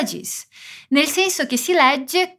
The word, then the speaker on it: legge: Aqui você precisa se concentrar na pronúncia de legge, que tem dois Gs, e na pronúncia do S em scrive, que não tem uma vogal antes.